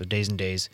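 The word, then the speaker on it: Days N Daze: Sooner those tomorrows cease to come when you All right, and that was Days and Days, and that's spelled D-A-Y-S, the letter N, and then D-A-Z-E. So Days and Days, and the song is called Advice.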